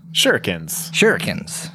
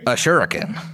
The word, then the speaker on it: shuriken: This sack, you open it up and it contains shurikens? Is that how you say it? Ninja stars? – Shurikens. – A shuriken.